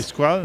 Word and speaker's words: Skrulls: Skrull. S-K-R-U-L. – Les, Scrolls. Les Skrulls. Les c'est comme ça qu'ils le prononcent.